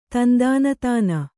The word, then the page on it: ತಂದಾನತಾನ: Kannada